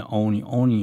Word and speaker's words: 鬼: 啊，那么这是这个恶鬼哈，Oni 用一个日文的 Oni 的发音就是 Oni 就是鬼啊。